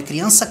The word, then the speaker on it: crianza: A palavra criança vem de criação, é criança com Z, mas a pronúncia é com S.